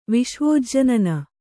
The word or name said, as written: ವಿಶ್ವೋಜ್ಜನನ